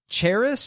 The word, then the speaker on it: Charis: And Charis, C-H-A-R-I-S, Charis, Charis, Bible College in Bible College.